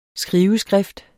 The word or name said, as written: skriveskrift